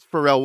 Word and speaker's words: Pharrell: I believe Nile Rodgers and Pharrell Williams, it's Pharrell Williams, right? – Yeah. – Who had that big giant hit. That's how you say it.